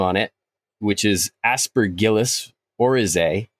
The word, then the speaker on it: oryzae: That's how I've always heard it pronounced, actually. Aspergillus Oryzae. – Okay. – Yep. – I've heard some people say Oryzae, and I don't know which one is correct, but I'm going to stick with Oryzae for now until I'm shown that I'm wrong, and then I will cease and desist.